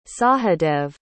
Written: sahadev

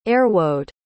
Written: ayrwode

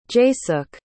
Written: jaysukh

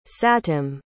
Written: satem

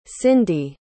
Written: cyndy

